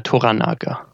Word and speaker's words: Toranaga: Toranaga.